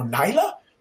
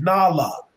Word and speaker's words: Nala: they go Nyla, Nola, Nula. I said no, it's Nala, like the African pronunciation, Nala.